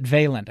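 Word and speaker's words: Vaillant: Is it Valant, I think is how he pronounces the name.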